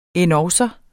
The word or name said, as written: endogså